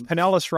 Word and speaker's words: Pinellas: Well, because Pinellas rhymes with penis, and a lot of these guys are dickheads.